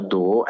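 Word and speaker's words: Do (道): Tao, giapponese, si pronuncia Do, è la via, il metodo, è anche il processo cosmico, il processo del mondo.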